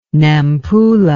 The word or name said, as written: Nampula